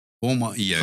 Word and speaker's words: HOMA-IR: Homa-IR. Então, é um jeito... – Como é que fala isso? – Roma-IR.